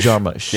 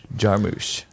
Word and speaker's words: Jarmusch: Jim Jarmusch. – Jarmusch. – Yep. – Jarmusch.